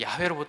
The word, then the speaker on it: 야훼: 정확한 히브리 이제 그 발음은 이제 야훼로 보통 발음을 하게 되는데요.